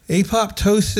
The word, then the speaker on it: apoptosis: Apoptosis. What do you know about it? – Apoptosis. – Is that how it's pronounced? – Yeah.